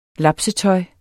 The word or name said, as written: lapsetøj